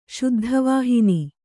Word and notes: ಶುದ್ಧವಾಹಿನಿ: kannada

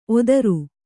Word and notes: ಒತ್ತೇರು: ottēru

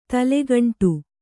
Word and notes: ತಲೆಗಂಟು: tale gaṇṭu

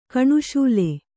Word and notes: ಕಣುಶೂಲೆ: kaṇuśule

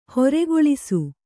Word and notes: ಹೊರೆಗೊಳಿಸು: kannada

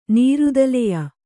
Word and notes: ನೀರುದಲೆಯ: nīrudaleya; Kannada

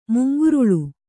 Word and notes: ಮುಂಗೊರಲ್: mungoral

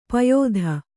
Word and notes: ಪಾಯ್ತ: pāyta

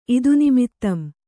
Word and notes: ಇದುನಿಮಿತ್ತಂ: Kannada

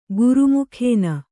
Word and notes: ಘರ್ಮಕಣ: gharma kaṇa; Kannada

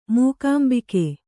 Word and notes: ಮೂಕಾಂಬಿಕೆ: mūkāmbike

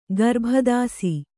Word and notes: ಗರ್ಭದಾಸಿ: garbha dāsi

